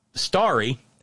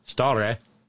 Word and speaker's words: stare: So, starry, S-T-A-R-R-Y. No, stare, S-T-A-R-E. D-E-C-I-S-I-S is not decisis.